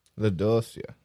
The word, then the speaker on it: LaDouceur: LaDossier. – Well, it's spelled LaDOUCEUR. LaDossier.